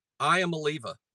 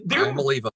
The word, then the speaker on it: Iamaleava: Yeah, it's pronounced exactly like it's written, which doesn't sound like much help, does it? But it literally that is zero help. – I am a Leva. – Okay, but you can't say it's written like this. I-A-M-A-L-E-A-V-A. – I'm a Leva.